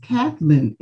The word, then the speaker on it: Kathleen: And I always respond, no, it is pronounced Kathleen exactly as written.